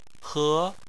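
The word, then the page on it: he: tone 2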